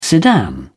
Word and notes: sedan chair: British English

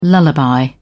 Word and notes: lullaby: British English

